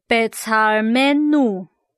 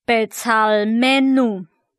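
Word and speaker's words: Betzalmenu (בְּצַלְמֵנוּ): 我们的形象，这整个字读音是 Betzalmenu， Betzalmenu。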